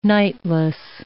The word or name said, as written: nightless